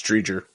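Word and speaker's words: Driedger: How do you say his last name? Dreider? – Chris Dreger. – Dreger. – I think it's Dreger.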